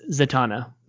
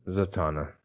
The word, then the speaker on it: Zatanna: You can't vote for it unless you pronounce it right. It's Zatanna. – Zatanna.